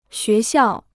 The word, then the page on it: 学校: xué xiào